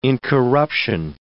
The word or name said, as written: incorruption